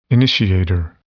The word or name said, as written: initiator